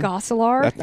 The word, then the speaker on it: Gosselaar: How do you pronounce his last name? – Gosselaar.